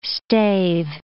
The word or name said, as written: stave